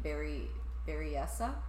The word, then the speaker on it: Berryessa: at Lake Berryessa. I don't know if I'm pronouncing that right. – That sounds right.